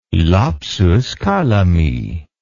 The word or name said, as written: lapsus calami